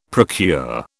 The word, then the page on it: procure: British English